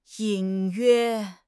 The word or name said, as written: 隐约